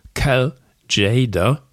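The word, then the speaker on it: Cal Tjader: Davor Cal Jader, wie ich jetzt endlich mal rausgefunden habe, so spricht man den aus.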